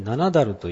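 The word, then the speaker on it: 七滝: それから河津七だる、七つの滝と書きまして七だるという不思議な発音をするんですけれども、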